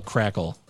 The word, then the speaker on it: Krackel: K-R-A-C-K-E-L. Crackle.